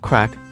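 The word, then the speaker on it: craic: 作者举出了与一般办公室企业的不同之处：一，The Craig，读作 Crack。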